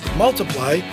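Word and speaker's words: ×: On July 19, 2014, Ed Sheeran was at number one on the UK album chart with X, pronounced Multiply, his second studio album.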